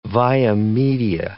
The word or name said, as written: via media